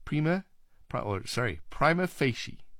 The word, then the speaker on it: prima facie: The main stress falls on the first syllables of the second word, fei, with a secondary stress on the first word, pri. Prima facie is the way it should be pronounced.